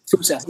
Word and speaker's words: fossa: I always like pronounce wrong, but did you see fosses or fooses? – Fossa, yeah, uh,